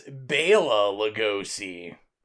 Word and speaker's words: Bela Lugosi: Whatever reason, yep, never saw the iconic, you know, career-making, genre-making, let's face it, Bela Lugosi version. – Let me correct you there. As someone that watched 8 million featurettes, there was an argument that it's Bela Lugosi.